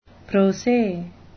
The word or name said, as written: pro se